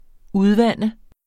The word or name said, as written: udvande